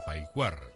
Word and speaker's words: Paicuar: Allí, en la quebrada de los recuerdos, como se conoce en español a Paicuar, tal como se pronuncia en el lenguaje cumanagoto, nació la Barcelona mestiza.